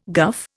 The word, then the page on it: guff: Indian